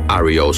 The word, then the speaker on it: REO: Al principio, sus miembros le decían Rio, pero con el fin de diferenciarse de la marca de camiones, decidieron usarlo en forma de iniciales, lo que dejó el grupo como REO Speedwagon y como lo estamos pronunciando correctamente aquí en el programa.